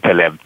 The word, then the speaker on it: Taleb: You seem to be a big fan of Mr. Am I saying it right? Taleb? How do you pronounce his name? – Yeah, it is actually Nassim Taleb. You're good.